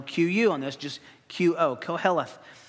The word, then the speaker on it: Koheleth: Koheleth, we're going to come back to this word over and over and over.